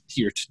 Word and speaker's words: Peart: It is Peart, not Pert. So.